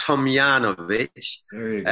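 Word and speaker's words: Tomljanović: Uh, obviously, I watched all of her matches, but the last round against Ajla Tomljanovic uh, is how it's pronounced.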